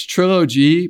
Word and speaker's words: Trill OG: And if you want to look it up, it's Trilogy, but it's spelled Trill OG.